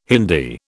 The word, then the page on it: Hindi: British English